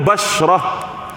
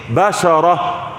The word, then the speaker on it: بشرة: احنا نقول، بس احنا بننطق غلط ماشي، لكن احنا نقول بشرة، صح؟ يقول لك جاب كريم بشرة، البشرة يعني الجلد. هو كلمة بشرة في اللغة العربية نطقها صحيح ايه؟ بشرة.